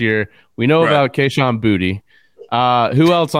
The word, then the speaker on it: Boutte: Everybody has said Kayshon Boutte. He said this week, talking with reporters, it's Booty. Booty. He said it's pronounced Kayshon Booty.